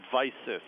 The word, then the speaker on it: Visus: Did I pronounce that correctly? – Yeah, that's right. It's called Visus. – Visus. – The way we're pronouncing it. Yeah, change in Latin.